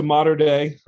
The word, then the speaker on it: Mater Dei: And I've heard several different ways of saying your high school. So I'll let you tell us how the proper pronunciation for your high school. – Yeah, so I went to Mater Dei High School.